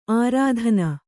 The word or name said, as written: ಅರತ್ನಿ